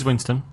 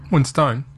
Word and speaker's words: Winstone: Oh, it is Winston. – Oh. – Winstone. – Yeah, it's written Winstone, but it's actually Winston. – Wow. – Oh. – At least that's how everybody in England pronounced it when I was there.